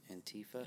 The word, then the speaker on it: Antifa: What I want to talk about, is it Antifa? Is it Antifa? How do you pronounce it? – Antifa. – Antifa. – That's what I thought.